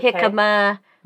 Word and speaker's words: jicama: And then you want to have some inulin too, which inulin would be onions, Jerusalem artichokes, jicama, okay.